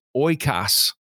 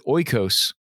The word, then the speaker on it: oikos: It's the word oikos. You can also pronounce it oikos if you wish. It's O-I-K-O-S, oikos, O-I-K-O-S.